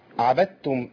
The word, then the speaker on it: عبدتم: عبدتم إضغام الدال تضغم في التاء إضغام متجانسين صغير، دال ساكنة عبد وتاء متحركة، والدال الساكنة اللي بتاتي مع التاء زي عدتم.